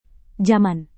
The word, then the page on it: jaman: Indonesian